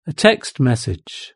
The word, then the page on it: a text message: British English